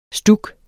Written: stuk

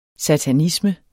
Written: satanisme

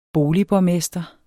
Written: boligborgmester

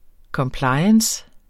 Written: compliance

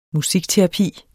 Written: musikterapi

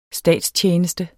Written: statstjeneste

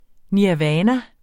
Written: nirvana

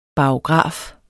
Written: barograf

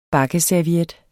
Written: BAKKESERVIETTER

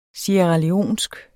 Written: sierraleonsk

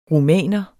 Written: rumæner

